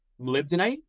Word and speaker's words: molybdenite: Molybdenite.